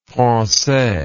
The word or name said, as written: francaix